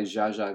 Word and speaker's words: Zsa Zsa: And that is why Zsa Zsa Gabor is spelled Z-S-A, Z-S-A. The theory is like, if S is sh, then SZ is sh, but pronounced the way you do Z. So, s. If Z is z, then ZS is Z, but pronounced the way sh is. So, z.